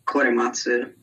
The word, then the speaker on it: Korematsu: All right, first of all, I'm actually pretty surprised that you nailed that pronunciation, Korematsu.